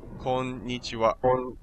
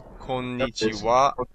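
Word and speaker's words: konnichiwa (こんにちは): Konnichiwa. And the greeting for evening is konbanwa. Now the last ha is actually pronounced as wa here because it's the topic particle, which I'll cover in another lesson.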